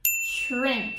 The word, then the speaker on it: shrimp: Shrimp. Tem esse chiado, tá? Shrimp no comecinho da pronúncia.